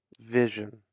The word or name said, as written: Vision